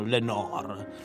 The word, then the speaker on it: Lenoir: Lenoir, anche se Lenoir, nonostante sia un cognome di origine francese, lui l'ha sempre pronunciato Lenoir.